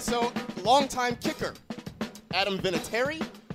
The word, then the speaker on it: Vinatieri: I go Vinatieri.